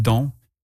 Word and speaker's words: donc: You'll also hear bain used with donc, the word for therefore, so, or thus, pronounced donc, and placed before bain for additional emphasis.